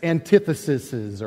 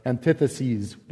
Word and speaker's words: antitheses: So last week, Pastor Scott taught, and we started into what are called the 6 antitheses, or antitheses, depending on who you ask how to pronounce it.